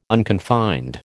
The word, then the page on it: unconfined: American English